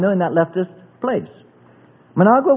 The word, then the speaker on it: Managua: Managua. How do you pronounce it again? Managua. Thank you.